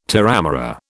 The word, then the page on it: terramara: British English